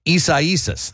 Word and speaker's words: Isaias: I found out how to say it correctly. Yesterday, I just called it Isis. Isaias. There you go.